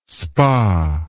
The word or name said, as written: spa